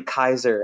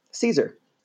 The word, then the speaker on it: Caesar: Caesar, we spell it C-A-E-S-A-R, but it's actually Kaiser. And the German word Kaiser is a literal preservation of the original pronunciation of Caesar. So it's not Caesar salad, it's Kaiser salad.